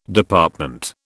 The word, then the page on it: Department: British English